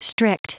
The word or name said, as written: strict